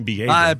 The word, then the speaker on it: Bieber: Justin Bieber. – Bieber. – All right. Justin Bieber. – In Germany, it would be because the I is pronounced first. – So. Oh, like Steen or Stein.